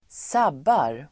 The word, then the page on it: sabbar: [²s'ab:ar]